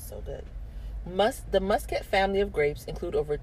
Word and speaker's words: Muscat: Muscat.